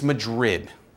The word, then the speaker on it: Madrid: However, I think it's an Iowa thing, because we call things like Madrid. It's Madrid.